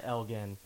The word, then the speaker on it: Elgin: So from now on, I will try to remember to call it Elgin. It makes me want to punch him in the face more that it's pronounced that way, but that's just me. – Because the towing company that's downtown is Elgin's, and it's spelled the exact same way. – Yeah. – And G-I-N is Jim. – He's an asshole, because his name has a hard G.